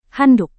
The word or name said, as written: handuk